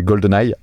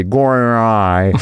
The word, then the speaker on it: GoldenEye: Ensuite, pour ce qui est de Tina Turner, euh, exactement comme toi, pour moi, Tina Turner, question de génération aussi, c'est GoldenEye avec euh, ah bah tiens, on parlait de John Martin. C'est pas GoldenEye, c'est GoldenEye.